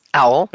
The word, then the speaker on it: OWL: I'm not sure if you pronounce it as OWL Moments. So if you can elaborate, what is an OWL moment? – You do pronounce it as OWL.